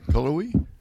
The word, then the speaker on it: Cullowhee: C-U-L-L-O-W-H-E-E. – You're going to have to go a little slower than that. Go ahead. What now? – Cullowhee. – C-U-L-L-O-W-H-E-E. – That sounds right. Cullowhee? – Well, I mean... Is that what we're going with? – Yeah. We're going with Cullowhee? – Cullowhee, North Carolina.